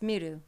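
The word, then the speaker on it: miru (見る): Miru. – Miru. Okay. It's pronounced miru.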